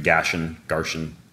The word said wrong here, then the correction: Ganesh: Ganesh. – Oh, I was close. And it's not a doctor. It's a Hindu god, Ganesh.